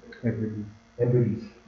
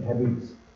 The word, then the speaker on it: Hebrides: Just after the Second World War, there was a massive revival in, I don't know how to pronounce this word, it's the Hebrides, the Scottish Island? – Hebrides. – The Hebrides.